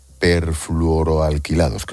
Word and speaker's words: perfluoroalquilados: ¿Qué son los ácidos perfluoroalquilados? Creo que lo he dicho bien. – Sí, sí.